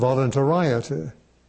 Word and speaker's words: voluntariety: Voluntariety. That's spelled V-O-L-U-N-T-A-R-I-E-T-Y. You want to pronounce it voluntariety. It's voluntariety. This corresponds to the how that we talked about before.